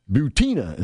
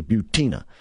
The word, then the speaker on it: Butina: Then you got the arrest of Maria Butina, is how I'm pronouncing her name. It just sounds more feminine to me, Butina.